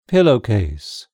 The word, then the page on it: pillowcase: British English